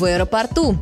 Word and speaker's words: аэропорту: Мы говорим «в аэропорту», и там ударение уже уходит на последний слог.